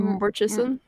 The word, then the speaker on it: Murchison: Murchison. – No, you got it. No, you said Munchausen Mountains. You gotta keep it. – No. – How do you Murchison? – The Murchison.